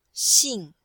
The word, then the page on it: xing4: female